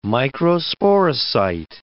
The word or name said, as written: microsporocyte